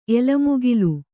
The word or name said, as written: ಎಲಮುಗಿಲು